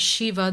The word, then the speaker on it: Shiva: And in the, in the course we learn that Shiva, the word has three syllables, the Shiva, which means body with life in movement.